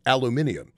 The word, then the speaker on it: aluminium: The shortage of aluminum for the Germans in the Second World War, or as the Brits say, aluminium, the shortage meant that the Germans in the Second World War, their supply chain was so affected.